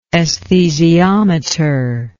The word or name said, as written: esthesiometry